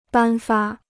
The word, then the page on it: 颁发: bān fā